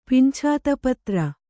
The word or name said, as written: ಪಿಂಛಾತಪತ್ರ